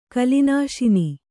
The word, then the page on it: ಕಲಿನಾಶನ: kalināśana